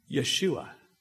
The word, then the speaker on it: Yeshua: And that his name, Yahashua, or Yeshua, is the same Hebrew pronunciation for Jesus. Yeshua.